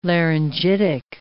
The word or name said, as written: laryngitic